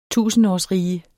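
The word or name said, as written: tusindårsrige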